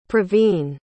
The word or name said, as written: pravin